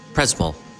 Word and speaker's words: Przemyśl: And could you pronounce exactly where we? It's Przemyśl. Przemyśl. This town where we are. – This Przemyśl. – I'm sorry, Przemyśl. I pronounce it completely different.